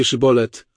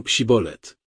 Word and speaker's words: szybolet: Mówię więc, że kiedy przekraczają tutaj Jordan, on mówi powiedzcie szybolet, a oni mówią psibolet.